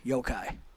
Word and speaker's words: yokai: Yokai.